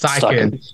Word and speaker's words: Steichen: Yes, Steichen. Yeah, that's how you say it.